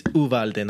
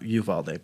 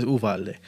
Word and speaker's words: Uvalde: It's Uvalde, not Uvalde, by the way. Not saying you said it wrong, but a lot of people say Uvalde. It's Uvalde. That bothers me.